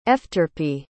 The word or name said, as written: efterpi